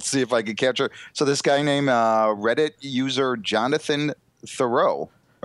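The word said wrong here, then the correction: Theriot: Or not Thoreau. What was the Cubs player? It's spelled the same way. The Riot. What is it? Terrio. – Terrio. – Is that how you pronounce it? Mm-hmm.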